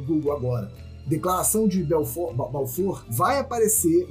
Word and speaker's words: Balfour: Se escreve Balfour, mas as fontes aqui afirmam que é Balfour o nome dele, porque o nome dele era Arthur Balfour, tá?